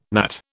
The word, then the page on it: gnat: British English